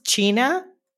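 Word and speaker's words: Txina: It's pronounced China, which actually means Chinese, okay? But the thing is that the TX comes because I lived briefly, very briefly, in the Basque country. And they use TX as CH.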